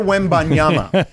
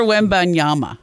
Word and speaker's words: Wembanyama: Is that Victor Wembanyama. – Victor Wembanyama.